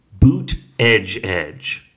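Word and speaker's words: Buttigieg: The guy who's actually running for president and not his husband insists that it's pronounced boot edge edge. That's how he in his Twitter bio says that you should say his name, boot edge edge.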